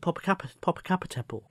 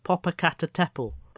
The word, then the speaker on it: Popocatepetl: I've been for years calling it Popocatepetl, but it's Popocatepetl. Popocatepetl.